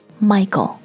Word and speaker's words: Michael: And of course 在一个讲德语的国家里，他们看到 Michael 的时候，他们的发音是 Michael. And so 这个单词里面的 k 的声音就完全没有，因为是 h 的音。 So, Michael.